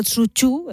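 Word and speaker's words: Tsuchu (蹴鞠): Por eso la FIFA suele decir que realmente el origen del fútbol, entre comillas, es un deporte chino que se llamaba Kuju, que se llamaba Tsuchu, realmente es la pronunciación,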